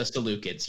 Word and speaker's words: Seleucids: I'm going to butcher the name again. The Seleucids. Does that sound correct? – The Seleucids, yeah.